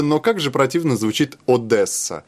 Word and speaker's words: Одесса: Дело в том, что по-русски говорят Одесса. Ну, вот русское произношение. А по-украински Одесса.